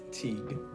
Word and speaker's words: Teague: Teague. – Teague. – It's Dutch. – I can't pronounce it, so... – It's T-E-A-G-U-E. It's a Dutch last name.